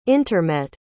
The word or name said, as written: intermet